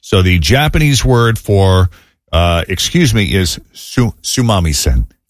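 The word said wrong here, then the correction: Sumimasen (すみません): I added a syllable to this. Yeah. Sumimasen. Sumimasen. I said it right.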